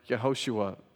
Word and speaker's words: Yehoshua: The way that they would see it, it's not like this because their alphabet is different, but it sounds like Yehoshua. The first syllable, that Y-E-H, would be short for the proper name of God, which is Yahweh.